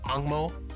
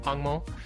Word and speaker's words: Hangmo: So, one thing I want to talk about, since you brought up the Hong Mao, Hong Mao is obviously the Mandarin pronunciation, but the the Taiwanese or the Hokkien pronunciation is Hangmo, Hangmo.